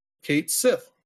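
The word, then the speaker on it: Cait Sith: In Final Fantasy VII Rebirth, Kate Sith is pronounced Kate Sith.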